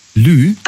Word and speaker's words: Lieu: Lieu. Also Lieu, das schreibt man also L-I-E und dann U.